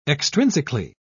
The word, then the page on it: extrinsically: British English